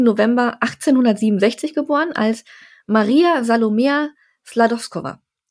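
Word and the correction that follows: Skłodowska: Sklodowska, Entschuldigung. Ich werde diesen Namen nicht mehr aussprechen, zum Glück. Mhm. Wie war die nochmal? Sklodowska.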